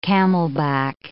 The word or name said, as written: camelback